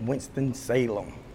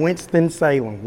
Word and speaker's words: Winston-Salem: So I know that you moved to Winston-Salem. Is that how you pronounce it? – Winston-Salem. – Winston-Salem. – You got to say it with a twang, Winston-Salem. Like, you got to put that Salem in there.